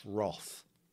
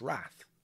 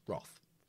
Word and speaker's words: wrath: Again, it's pronounced wrath, unless you're an American, and then it's wrath. But it's wrath.